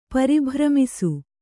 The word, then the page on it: ಪರಿಭ್ರಮಿಸು: Kannada